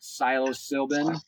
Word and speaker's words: psilocybin: That talks about psilocybin. That's the right way to pronounce it.